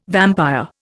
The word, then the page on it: vampire: Indian